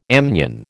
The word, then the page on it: amnion: American English